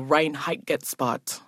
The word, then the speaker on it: Reinheitsgebot: It was law called the Reinheitsgebot. I said it right.